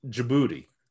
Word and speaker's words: Djibouti: I think you actually pronounce the country Djibouti, and I think that's really the way you pronounce it.